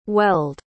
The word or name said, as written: welled